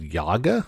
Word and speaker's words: Yaga: It's called Yaga. I believe that's how it's pronounced. Y-A-G-A. It will also launch on November 12th.